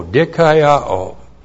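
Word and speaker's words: dikaiao (δικαιόω): Therefore, and then the verb dikaiao. Looks like that in the Greek, D-I-K-A-I-O, long O, dikaiao.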